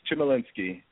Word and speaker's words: Chmielinkski: How do you say it? – That's right. It's Chmielinkski. You got it.